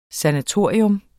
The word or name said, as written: SANATORIET